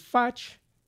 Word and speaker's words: Futch: Maui Realtor Dave Futch, rhymes with much, Jerry Valdrez from Hawaiian Steel, Malama Kula, and the Wailuku Coffee Company.